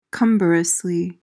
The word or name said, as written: cumbrously